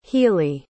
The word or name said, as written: healy